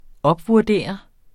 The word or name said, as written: opvurdere